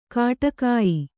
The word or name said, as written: ಖಡಗಾಯಿ